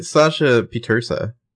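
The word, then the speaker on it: Sasha Pieterse: Yeah, Sasha Petersa, which is yes. How you pronounce her name.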